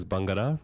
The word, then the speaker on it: Bangarra: The pronunciation is Bangarra. – Oh, Bangarra, I'm so sorry.